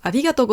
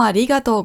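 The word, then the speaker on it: Arigato (ありがとう): Ah ja, Betonung. Arigato. Beim I. Arigato. Nicht arigato. Ja, nicht auf dem To hinten, sondern arigato. Da, da, da, da. Da, da, da, da. Also unten, rauf, runter.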